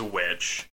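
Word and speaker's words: The VVitch: The movie itself is titled The Vivitch, with two V's instead of a W. – So I mean, the you still pronounce it as witch.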